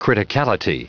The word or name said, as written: criticality